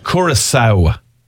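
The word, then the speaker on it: Curaçao: But actually, they decided on the W because you make a W sound at the end when you say Curacao.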